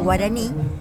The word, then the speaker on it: guaraní: Acuérdense que las lenguas, por ejemplo, el guaraní es una lengua aguda, va todo acentuado en la última sílaba. Guaraní, Paraná.